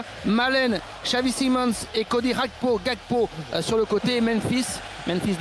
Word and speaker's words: Gakpo: Par exemple, non, je vais pas la jouer Rakpo, tu vois. Je vais dire Gakpo. Non, parce que après, sinon, ça veut dire qu'on va prononcer vraiment la néerlandaise et là, on n'y arrivera pas.